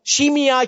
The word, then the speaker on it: Shimei: A man by the name of Shimei. Uh, the, you know, the Bible is spelled S-H-I-M-E-I. Uh, it can be pronounced Shimei, uh, but the correct spelling is Shimei, okay?